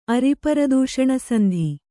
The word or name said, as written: ಅರಿಪರದೂಷಣಸಂಧಿ